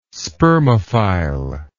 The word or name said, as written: spermophile